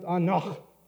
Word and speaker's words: anach: And that word is this, and it's pronounced anach. It's a word that you have to clear your throat at the end if you want to get it out right.